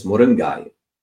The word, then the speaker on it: moringa: Americans may say moringa or Westerners, but if you put a Tamil accent, it's moringai, moringai. So G-A-Y, okay, moringai. So that's a moringai. It's a different way to pronounce it.